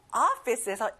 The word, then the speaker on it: office: 자, 오피스가 아니라 어 발음이에요. Office.